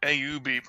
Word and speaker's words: AUB: In 2015, he helped set up the Athlete Microbiome Bank, or AUB, pronounced like orb, which aims to use to have harvested from feces to help improve athletes' performance and overall health.